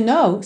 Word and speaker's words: know: Lembrando que esse verbo to know, saber ou conhecer, tem a mesma pronúncia de know, não. Ok? O K é mudo e esse N-O-W é fechadinho.